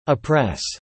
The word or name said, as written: oppress